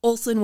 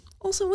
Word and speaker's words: Olson: Now it's just Olson with an O, because, you know, there's commonly S-E-N, but just Olson with an O.